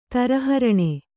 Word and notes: ತರಹರಣೆ: taraharane

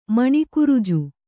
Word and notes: ಮಣಿಕುರುಜು: maṇi kuruju

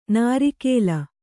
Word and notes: ನಾರೀಕೇಲ: nārīkēla

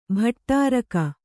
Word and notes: ಭಟ್ಟಾರಕ: kannada

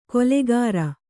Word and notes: ಕೊಲೆಗಾರ: kolegāra

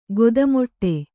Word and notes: ಖಾತೆಮಾಡು: khāte māḍu

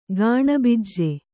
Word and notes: ಗಾಣಬಿಜ್ಜೆ: gāṇa bijje

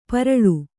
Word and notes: ಪೇರಲ: Kannada

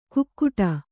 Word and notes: ಕುಕ್ಕುಟ: kukkuṭa

